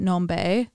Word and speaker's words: Nombe: It's called Weirdo by Nombe, I believe is how you pronounce it. Nombe. N-O-M-B-E, but the N is capitalized and then the B is capitalized.